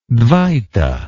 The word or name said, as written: dvaita